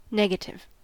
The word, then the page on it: negative: American English